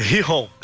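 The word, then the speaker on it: Gijón: O, no final de semana, é o Real Madrid ganhou de 5x1 do Guijon. Guijon, Guijon, como é que fala isso aí? Você que manja do é, Sporting. É, Rijon, Sporting Rijon.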